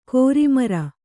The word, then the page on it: ಕೋರಿಮರ: kōri mara